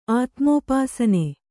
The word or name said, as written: ಆತ್ಮೋಪಾಸನೆ